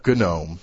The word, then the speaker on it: GNOME: and it's actually called GNOME, you know, that hard G, like GNU.